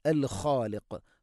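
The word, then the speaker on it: അൽ ഖാലിഖ് (الخالق): ഹുവല്ലാ ഹുവല്ലാ എന്ന് പറയരുത്, ഹുവല്ലാഹുൽ ഖാലിഖ്, അൽ ഖാലിഖ്. ഖ തൊണ്ടയുടെ മുകൾ ഭാഗം, ഖാഫ് അതിൻ്റെയും മേലെ ചെറുനാക്കിൻ്റെ അടുത്ത്, അതുകൊണ്ട് ശ്രദ്ധിക്കുക. അൽ ഖാലിഖ് രണ്ടും സ്വരഭാരത്തിൽ ഉച്ചരിക്കേണ്ട അക്ഷരമാണ്, ഖാലിഖ് എന്നല്ല. അൽ ഖാലിഖു, ഹുവല്ലാഹുൽ ഖാലിഖുൽ ബാരിഉ, അൽ ബാരിഉ ഹംസ, അൽ ബാരിഉ ഹംസ കൃത്യമായിട്ട് ഉച്ചരിക്കണം.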